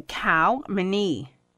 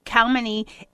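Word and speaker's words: Khao Manee: So we're going to start with, and they're not in any particular order, but from Thailand, there is a breed called the Khao Mani. I hope I'm saying that right. It's K-H-A-O-M-A-N-E-E. So, Khao Mani, it means white gem.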